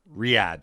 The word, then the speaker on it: Riyadh: What did you call it? – Riyadh. I said Rida. Damn it. I asked him for the pronunciation prior to it as well, and he told me. And then I practiced it. And then I got it wrong.